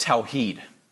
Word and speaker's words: Tawheed: The term that you will see Muslims using to describe their doctrine of God is this term on your outline, pronounced Tawheed.